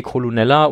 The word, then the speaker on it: Colonel: Verschiedene Leute haben sich wahrscheinlich schon irgendwie die Haare ausgerauft, weil das Colonel geschrieben wird und im Englischen Colonel ausgesprochen wird.